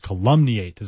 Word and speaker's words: calumniate: Like calumny is on that first syllable, whereas calumniate is on that maybe second or third.